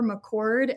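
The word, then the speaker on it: McChord: It's Jennifer McCord, and that is spelled a little strange.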